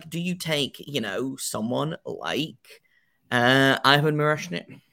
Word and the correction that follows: Miroshnichenko: I always butcher his name. I do apologize. Miroshnichenko. Ivan Miroshnichenko, who I believe went in the 15 to 20 range last year.